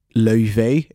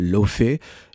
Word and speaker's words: Laufey: L'exemple de This Is How It Feels avec l'artiste de jazz islandaise Laufey, et pas Laufey, j'ai vraiment appris à le prononcer à la manière locale,